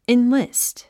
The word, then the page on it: enlist: inlíst; インリィスト